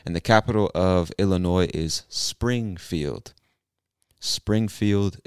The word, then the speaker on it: Illinois: Now, if you're reading this or if you've ever read the word, you'll see that the word ends with an S, but we do not pronounce that S. It is silent. Say Illinois.